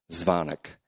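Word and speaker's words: Dzvonik: Steve Zvonik, and he told me this week that the D is silent, so it's Zvonik.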